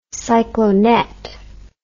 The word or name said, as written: cyclonet